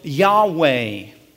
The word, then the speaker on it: Yahweh: He says, YHWH, that tetragrammaton, or Yahweh, it's sometimes pronounced. Jehovah is another way to pronounce it.